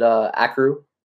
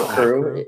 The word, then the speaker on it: Akrew: I mean, for a long time, they called it Akrew, and we're a crew, and so like our owner was like, yo, you need to tell them.